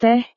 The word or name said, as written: teh